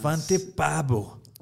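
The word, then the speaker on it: Svante Pääbo: Se llama Svante Paabo. – Svante Paabo. – Paabo.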